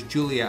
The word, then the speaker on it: Julia: Julia's Eyes, it's Spanish, so I guess we should pronounce Julia as Julia, that's how they would say it.